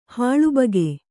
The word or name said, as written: ಹಾಳುಬಗೆ